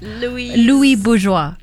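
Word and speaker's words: Louise Bourgeois: Louis Bourgeois.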